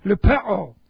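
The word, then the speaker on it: Lupeo (λυπέω): This is the word lupeo. Lupeo. L-U-P-E long O. Lupeo.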